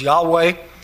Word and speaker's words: Yahweh: but more recently has generally been considered to be pronounced as Yahweh. When you hear those two different terms, you need to understand that's just basically a greater understanding of how Hebrew works and an understanding of how Hebrew was pronounced. And so what for a long time was considered to be Jehovah, we now recognize as probably something closer to Yahweh, though nobody's absolutely certain, but it's certainly closer to the Hebrew pronunciation.